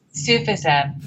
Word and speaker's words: Sufism: So are you still practice, is it Sufism? Is that what you pronounce it as? – Sufism.